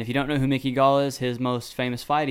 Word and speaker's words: Mickey Gall: He fought Mickey Gall, whatever, how you pronounce his name. – Mickey Gall.